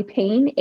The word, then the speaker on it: Pain: You can find me on TikTok and Instagram at Aly Pain, AlyPain. That's, yep, like ouch. Spell it like it feels is my last name.